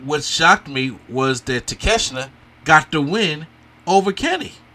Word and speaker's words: Takeshita: Six-man tag team match, Bullet Club Gold, Jay White, Juice Robinson, with Kenosuke Takeshita. Did I say it right? – Takeshita. – Okay. A little off, but I was proud of myself.